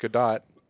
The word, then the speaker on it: Gadot: Gadot, yeah. – Which and then you got Gal Gadot. – Mm-hmm. – Which actually her name, last name is pronounced Gadot.